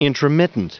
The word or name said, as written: intromittent